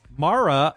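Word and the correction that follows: Maura: of Los Angeles. – Mara? – M-A-U-R-A. – Oh, Mora. Okay.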